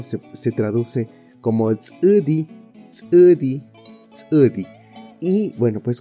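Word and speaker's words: ts'ʉdi: Eh, para escribir Sudi tenemos la consonante TS, glotal U, que es una U con la rayita abajo. Y tenemos la D y la I. Sudi.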